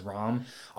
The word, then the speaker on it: Ram: Her name is spelled R-A-M, and I can totally understand why people would call her Ram, but the English anime did pronounce her name as Rom. Unlike Old Man Rom, the big muscular guy who was rooming with Felt, because he's also like Rom, R-O-M. – Yep. – But I just wanted to make this clear. Right. English pronounced it as like kind of like a harder O to almost A. Like, it's Rom. – Yeah. – Not Ram.